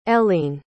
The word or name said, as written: ellene